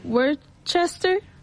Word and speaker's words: Worcester: So we have, I'm so sorry, we have Vinny from Worcester.